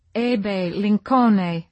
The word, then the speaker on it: Abe Lincoln: Two roses we choose, one milky pink, one a velvet red, are Queen Elizabeth and Abe Lincoln, pronounced Ebe Lincone at the nursery.